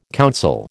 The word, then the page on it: counsel: American English